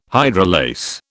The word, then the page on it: hydrolase: British English